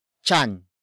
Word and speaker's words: tiyan: It is the focus marker that introduces the main topic of this sentence, and that is chan. Chan. Chan. Chan is spelled as T-I-Y-A-N.